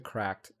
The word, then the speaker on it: Cracked: So the company Cracked, CRKD, you actually pronounce it Cracked, not whatever else you might think it was. I didn't know. I thought it was pronounced CRKD for the longest, and then I watched one of their videos, and they pronounced it as Cracked.